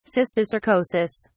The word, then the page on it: cysticercosis: English; British English